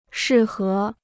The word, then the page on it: 适合: shì hé